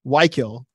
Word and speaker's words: Wykle: So I wrote it out phonetically. Wykill, WHY-KILL. I didn't tell him how to like emphasize, so he emphasized it wrong.